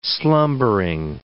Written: slumbering

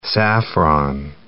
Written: saffron

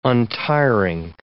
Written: untiring

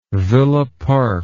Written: villa park

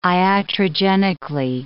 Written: iatrogenically

